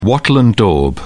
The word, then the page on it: wattle and daub: British English